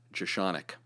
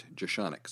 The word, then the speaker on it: Jeschonek: and it's pronounced Jeschonek, Robert Jeschonek.